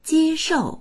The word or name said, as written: jie1shou4